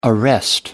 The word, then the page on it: arrest: American English